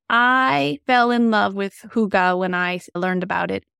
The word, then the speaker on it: hygge: There is a word called hygge, and it is a part of the Danish culture. Hygge is spelled H-Y-G-G-E,